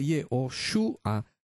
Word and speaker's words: Yehoshua: entonces tendría que leer Yehoshua, porque los masoretas le agregaron una U.